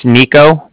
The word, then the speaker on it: Niko: Bank of Cardiff, and that's spelled C-A-R-D-I-F-F, as in Frank. Frank. My name's Nico. That's spelled N-I-K-O.